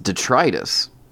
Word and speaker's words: detritus: Apparently it's pronounced detritus. – Yeah, that is one of those you hear sometimes. – No, apparently I've been saying it wrong forever.